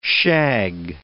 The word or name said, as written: shag